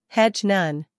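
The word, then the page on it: hedge: uk hedʒ; en-us hedʒ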